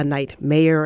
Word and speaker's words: night mayor: Some people call it a night mayor. And I always have to make sure I say it right, because if you say it wrong, it sounds like a nightmare.